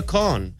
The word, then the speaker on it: Kahan: It's Noah Kahn. – No. – Oh, yes. – It is. – Hello. – It's Noah Kahn. Okay. The second A is silent.